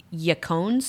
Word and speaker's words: yacon: And then something else that I've used a lot in the past is, and forgive me, I might say it wrong, is yacon syrup. – Oh. – So it looks like bacon with a Y.